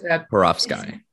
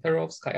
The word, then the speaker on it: perovskite: So it's perovskite. – P-E-R-O-V-S-K-I-T. Perovskite.